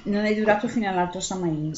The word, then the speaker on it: Samhain: la pronuncia Samain rispetto alla pesantezza di Sowin.